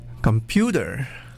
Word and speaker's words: computer: The O and the E turn to the lazy schwa sound. It's not computer, it's computer. Computer.